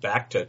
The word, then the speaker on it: Bacta: It's pronounced Bacta tank.